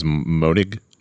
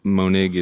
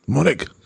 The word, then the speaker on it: Monig: Because I always thought it was Monig. – Monig is the most common mispronunciation. – But you got a nice, firm German last name. – Yes. Monig. – Monig.